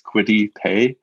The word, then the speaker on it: Quiddy Pay: It's spelled a lot like cutie pie, and that's how I was hoping it was pronounced. But no, it's Quiddy Pay.